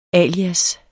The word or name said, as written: alias